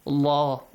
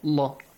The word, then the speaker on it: Allah: If you have a dhamma or a fatha right before the word Allah, the letter lam will be pronounced with a heavy sound. Allah, Allah, full mouth.